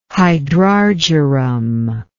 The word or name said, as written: hydrargyrum